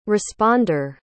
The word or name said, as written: responder